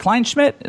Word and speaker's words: Kleinschmidt: It was I remember, Rob, it was you and then Jessica, is it Kleinschmidt? Did I say that right? – Yeah, you and Jessica Kleinschmidt, you guys were kind of rolling around.